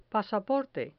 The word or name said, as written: pasaporte